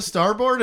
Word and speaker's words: Starbird: How do you pronounce it, really? Starbird. – Starboard. – I know, but nobody says starboard. – No. – Right. See, that's weird. That's how it's spelled. But I think we pronounce it starboard. Like B-U-R-D, not B-I-R-D.